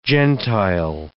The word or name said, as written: gentile